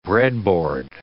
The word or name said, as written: breadboard